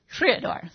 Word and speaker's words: Creador: Creador.